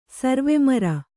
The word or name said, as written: ಸರ್ವೆಮರ